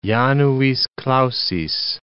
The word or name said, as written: januis clausis